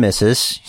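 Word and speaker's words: Miz & Mrs.: Or Brian and Brie versus Miz and Maurice. – It's Miz and Mrs. Say it right.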